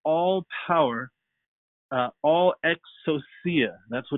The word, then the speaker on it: exousia: Well, in order to understand what that means and what Jesus was actually telling his church and telling his disciples, it comes from the word exousia, that's how you say it, E-X-O-U-S-I-A.